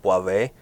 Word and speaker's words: Boivin: Cool little nugget about Christian Boivin. I think he kind of pronounces it with the N way, like, not accented very much, like almost like Boivé, like a little bit. A little French.